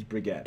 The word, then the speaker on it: Brigitte: Not Bridget or Brigitte, it's Brigitte.